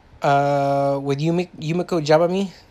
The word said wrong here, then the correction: Yumeko Jabami: Yumeko Jabami.